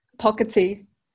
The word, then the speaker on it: Pocketi: Is that how you pronounce it? – Pocketi. – Pocketi Shop, sorry. I butchered it.